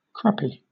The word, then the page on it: crappy: British English